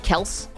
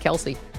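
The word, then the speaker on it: Kelce: Travis and Travis Kels. – Also, apparently it's not pronounced Kelsey.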